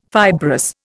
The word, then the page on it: fibrous: Indian